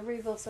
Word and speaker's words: Ribos: We have watched the fourth and final part of the Ribos operation.